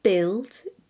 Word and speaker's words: build: Build, B-U-I-L-D, guard, guilty, laugh. Each of these words has a U that has no business being there, to be honest.